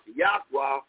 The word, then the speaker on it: YHWH: So when you're not using the proper name, so they hid the true name, YHWH, pronounced Yahweh, and then they manipulated you by putting the name Lord God and Jesus in place of it.